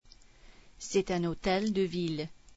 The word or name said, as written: hôtel de ville